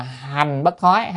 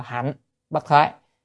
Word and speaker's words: hạnh bất thoái: Thì thứ nhất là vị bất thoái ha, rồi thứ hai là hành bất thoái, hay còn gọi là hạnh bất thoái, ở Việt Nam thì đọc là hạnh bất thoái.